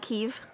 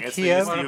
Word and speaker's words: Kyiv: So for us ignorant folks, we should call it Kyiv. – Kyiv is the... The way the Ukrainians... – You know why I say Kiev? – Chicken Kiev.